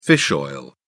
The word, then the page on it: fish oil: British English